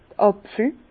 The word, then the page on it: d'r Àpfel: Bas Rhin; Herrlisheim